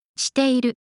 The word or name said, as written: shiteiru